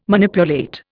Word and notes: Manipulate: Indian

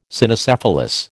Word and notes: Cynocephalus: American English

